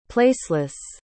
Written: placeless

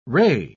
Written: ray

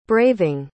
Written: braving

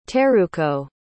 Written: teruko

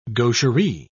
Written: gaucherie

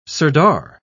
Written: sirdar

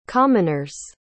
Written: commoner's

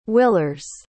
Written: willers

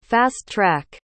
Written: fast-track

